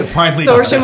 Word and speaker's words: Saoirse: Saoirse Mulligan, correct.